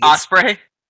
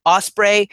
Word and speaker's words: Ospreay: I feel like I also say his name wrong every single time. Like, he's the one name when I say, like, Ospreay. – Ospreay.